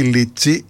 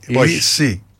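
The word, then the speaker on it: Lisi: Όχι Λίτσι, Λύση. – Τον Αλέσιο Λύση. – Ναι, γιατί πολλοί τον λένε Λίτσι. Βάζουν και το ταφ.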